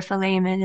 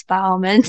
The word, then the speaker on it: Philemon: So the short epistle of Philemon, or Philemon is how you actually pronounce it. I'm bad at pronouncing it, though. How we say it in the South is Philemon.